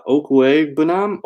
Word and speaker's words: Okwuegbunam: And then you got Albert, and I believe the correct pron- pronunciation of this is uh, Okwuegbunam, Okwuegbunam uh, versus Kansas City.